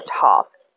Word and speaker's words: Tav: So you have Aleph, and you are going to have Tau or Tav. It also can be pronounced Tau or spelled T-A-U, depending on a certain knowledge or wisdom or whatever you read.